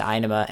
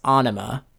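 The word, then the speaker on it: Ænima: And apparently Inema is the accepted pronunciation, but what they, to them, when they say it, it's Anima. Exactly, and it doesn't really have the same ring to it as Inema.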